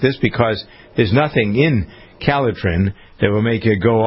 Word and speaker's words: Calotren: And you don't have to go on a crash diet with Kellitrin. By the way, it's spelled C-A-L-O-T-R-E-N.